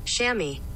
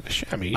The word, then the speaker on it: chamois: Shammy. – Oh, yeah, Shammy. Yeah. – I didn't know that's how it was spelled.